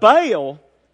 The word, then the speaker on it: Baal: When I took Hebrew at the University of Georgia, who knows if they got it right or not, but I think it's pronounced Baal. It's kind of like this little huffy puffy guttural thing that I can't do right.